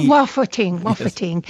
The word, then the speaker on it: waffeting: Well, it's my American pronunciation, I guess, but waffeting. W-A-F-F. E-T. – Oh, waffeting.